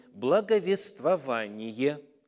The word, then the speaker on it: благовествование: Но ну вот ключевое понятие – слово истины услышав слово истины, благовествование, а, может быть, для кого-то, к сожалению, но орфографический словарь именно так заставляет нас в этом слове делать ударение.